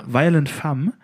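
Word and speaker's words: Violent Femmes: Ähm, von der Band, ich kann leider nicht so gut Französisch, aber äh, Violent Femme, spricht man das so aus? – Ja. – Ja? Ja. Violent Femme, also.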